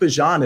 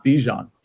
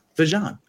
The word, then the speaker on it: Bijan: So FYI, by the way, Bijan Robinson, it's pronounced Bijan and not Bijan. He says he doesn't mind which way you pronounce it. I don't care which way you pronounce it. But I'm just letting you know the proper way to say Bijan Robinson is actually Bijan, and Travis Etienne is actually Etienne, not Etienne.